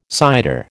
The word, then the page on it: cider: American English